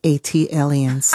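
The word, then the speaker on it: ATLiens: That is pronounced A-T-Aliens. – Ah, thank you. – Like Atlanta alien, A-T-Alien.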